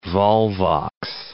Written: volvox

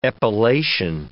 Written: epilation

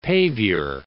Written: pavior

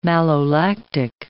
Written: malolactic

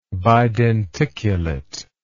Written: bidenticulate

